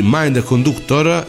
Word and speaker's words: Mind Conductor: di Little Witch Academia Stiamo parlando di Mind Conductor, il mio inglese fa pena, ho detto giusto? – Sì, sì, sì, no, sempre, tranquillo.